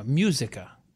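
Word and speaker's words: Música: No, Musica. Say it right. Come on, now try it. – It's Musica.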